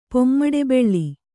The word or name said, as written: ಪೊಮ್ಮಡೆಬೆಳ್ಳಿ